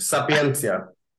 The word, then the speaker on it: Sapientia: And you are currently the head coach of, so it's called Sapientia. I probably butchered that one. – No, it was close enough. Sapientia.